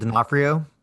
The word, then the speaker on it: D'Onofrio: And Vincent, I believe it's, I don't know how to say his last name, Donofrio? – Vincent D'Onofrio? – D'Onofrio, there you go.